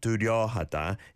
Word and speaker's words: 뚜렷하다: There's a 시옷 받침, bottom consonant, on 려. So it's not 뚜렷하다, it's 뚜렷하다. 뚜렷하다. 뚜렷하다. Give that some practice as well.